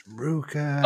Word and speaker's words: RVCA: It's pronounced Ruka. Oh my God, bro. I'm 32 years old and it's pronounced fucking Ruka.